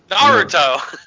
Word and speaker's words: Naruto: No, Naratu? Is that how you say it? What's the anime? – Naruto! – Yeah, see, that's how you know it's an absolute joke, because I didn't even pronounce it right.